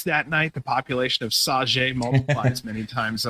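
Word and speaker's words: Sauget: So Saget is how it's said.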